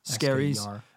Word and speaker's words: Skerries: Yeah, but it's actually pronounced, what, S-K-E-R, like scare? – Yeah, yeah, yeah. – Skerries. Not like the Freakies or the Terribles.